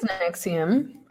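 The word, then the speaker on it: NXIVM: And it was really painful documentary to watch because, like, in that case, the guy who ran Nixium, is that how you pronounce it? – I think it's Nixium.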